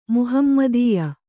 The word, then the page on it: ಮಹಮ್ಮದೀಯ: mahammadīya